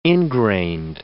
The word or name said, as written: ingrained